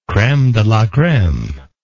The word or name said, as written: creme de la creme